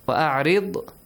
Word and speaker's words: وَأَعْرِضْ: Only the letter Ra has to be pronounced correctly. وَأْمُرْ heavily. وَأَعْرِضْ رِ lightly.